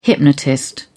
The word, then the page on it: hypnotist: British English